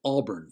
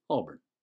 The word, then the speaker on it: Auburn: They pronounced Auburn as if there was an emphasis on the burn. They even enunciated the noticeable "earn" ending rather than sort of a soft ending of the "earn," which was commonly heard. With the soft ending, the listener hardly noticed it. Thus, it was pronounced Auburn. However, it was not unusual to find locals in Sugar Hill who varied the ending and more often than not pronounced it Auburn, as in A-W-B-U-N.